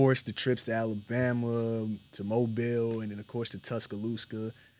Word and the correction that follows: Tuscaloosa: Lusa, my bad. – You're on a roll with these pronunciations. – Tuscaloosa, Livingston.